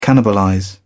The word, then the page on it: cannibalize: British English